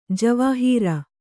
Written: ಜವಾಹಿರು

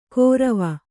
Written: ಗೊರವ

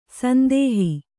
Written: ಸಂದೋಹ

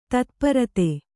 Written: ತತ್ಪೂರ್ತ

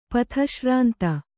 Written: ಪಥಶ್ರಾಂತ

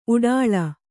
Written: ಊಟಳೆ

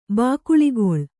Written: ಬಾಕುಳಿಗೊಳ್

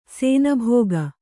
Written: ಶಾನಭಾಗ

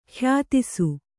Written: ಖ್ಯಾತಿಸು

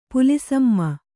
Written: ಪುಲಿಸಮ್ಮ

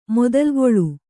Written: ಮೊದಲುಗೊಳ್

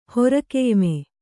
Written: ಹೊರಕೆಯ್ಮೆ

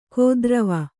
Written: ಕೋದ್ರವ

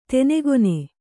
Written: ತೆನೆಗೊನೆ

